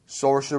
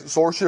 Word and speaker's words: Saoirse: ¿Estás seguro que esa es la pronunciación? – Saoirse Ronan. Así es, Saoirse Ronan. Tienes que hacerlo como inglés. Como inglés, como escocés.